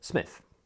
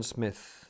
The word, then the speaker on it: Psmith: So I decided to adopt the Psmith. The P, I should add for your guidance, is silent as in phthisis, psychic, and ptarmigan.